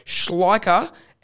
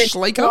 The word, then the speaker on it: Schleicher: It was a very good attempt. Don't worry, everyone butchers it. – Is it Schleicher? – Schleicher. It's, no, God, that's worse. No, it's Schleicher. It's said a lot easier than it's spelled. There's a lot of unnecessary letters that are thrown in there.